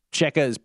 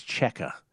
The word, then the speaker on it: Cejka: Cheka is spelled C-E-J-K-A. – C-E-J-K-A. Alex Cheka.